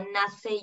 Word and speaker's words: Nasce: Y el estudio se llama Nace Yoga y Nace se escribe SC.